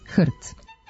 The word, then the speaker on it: HRT: S druge strane, skraćenicu za Hrvatsku televiziju ne izgovaramo slovo po slovo, nego kao jednu reč, HRT.